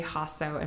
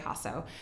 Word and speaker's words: Jasso: Hasso, and my last name is spelled J-A-S-S-O. So there's two J's right in a row, Jess J. Hasso.